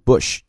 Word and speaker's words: Busch: 第二个词读 Bush，就是跟那个 B-U-S-H